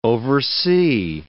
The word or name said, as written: oversee